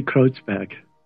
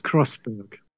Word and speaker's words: Kreuzberg: We say Kreuzberg. In English, it's Kreuzberg.